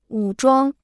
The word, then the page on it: 武装: wǔ zhuāng